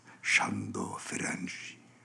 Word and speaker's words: Sándor Ferenczi: J'ai essayé de vous prononcer à la hongroise, Sándor Ferenczi.